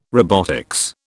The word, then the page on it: robotics: British English